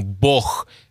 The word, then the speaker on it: бог: Например, вы слышали, как русские произносят слово «бог». Да, мы говорим «бог». Это южное произношение, которое стало литературной нормой русского языка.